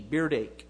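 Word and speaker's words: berdache: They had a name for them, and as best I've never heard it pronounced, but uh, it's B E R D A C H E, beardache.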